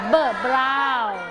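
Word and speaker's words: brown: คำนี้สีนี้ออกเสียงว่าออกเสียงสองเสียงตัว B กับตัว R เบอร์บราว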